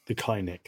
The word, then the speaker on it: kynic: I think the cynic in me is more looking at this. – The kynic, thank you. I didn't understand the true pronunciation of that. – Sorry, I mispronounced it first.